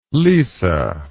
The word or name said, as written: lise